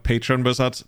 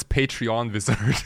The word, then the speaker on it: Patron Wizard: We just figured out it's actually called Patron Wizard because your chat couldn't agree how to pronounce it. – Oh, I thought it's Patreon Wizard. – but I think there's no E in there.